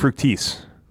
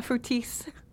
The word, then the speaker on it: Fructis: The only cosmetics he uses regularly are a face lotion in the mornings and Garnier Fructis. Did I pronounce that right? – Mm-hmm, you did. – Fructis. – Yeah, Fructis.